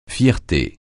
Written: fierté

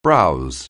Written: browse

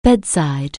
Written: bedside

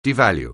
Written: devalue

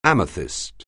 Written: amethyst

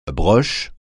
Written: broche

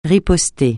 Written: riposter

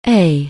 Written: aye

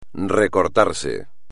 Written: recortarse

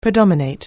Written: predominate

